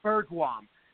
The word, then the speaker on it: Bergquam: Yeah, he's um yeah he's very uh very uh popular guy, very smart guy, uh, Ben Bargwam, and um, so Ben Bergquam is how you pronounce it.